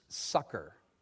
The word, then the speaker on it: succor: You have the word, looks like succor there. It's actually pronounced sucker.